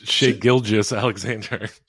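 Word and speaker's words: Shai Gilgeous-Alexander: Shai Gilgeous. It's Shai Gilgeous Alexander.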